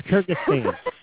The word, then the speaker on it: Kyrgyzstan: That's Kyrgyzstan. – Kyrgyzstan.